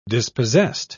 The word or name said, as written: dispossessed